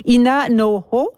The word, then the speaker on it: Ina Noho: pour la prononciation, Ina Noho. Alors, voilà, je l'ai dit comme il faut.